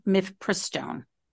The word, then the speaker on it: Mifepristone: You and I have done this interview now for a few minutes, and it's Mifepristone. Is that how you pronounce it? This is the drug so that people, right? Mifepristone.